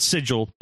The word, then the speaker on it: Sigil: Sometimes if you get into the city called Sigil, spelled Sigil, but you pronounce it Sigil.